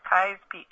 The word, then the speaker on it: Keyes: Okay, and Kai's, for folks who are new to Florence County, is K-E-Y-E-S, right? – Correct, yes. Yeah. Some people pronounce it Keys, but around here, all the locals, it's Kai's Peak, yeah.